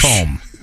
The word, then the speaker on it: Macomb: Wait, isn't it Macomb? – Macomb. – Macomb. – Yeah, I think the B is silent. – What about LaBrush? Macomb.